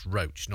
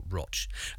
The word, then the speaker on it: Roach: By the way, the river flowing through Rochdale being the River Roach, pronounced Roach, not Roch.